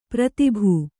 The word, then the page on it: ಪ್ರಾತಿಭ: prātibha